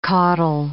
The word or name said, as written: caudal